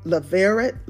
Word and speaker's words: Levirate: Levirate, Levirate marriage.